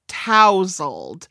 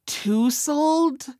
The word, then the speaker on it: tousled: I realized I had trouble pronouncing tousled, which I always thought was tousled. It's one of those words that you see a lot, but apparently no one ever says it because I've gotten through 43 years without knowing how to say it.